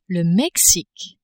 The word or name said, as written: Mexique